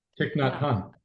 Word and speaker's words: Thich Nhat Hanh: Thich Nhat Hanh.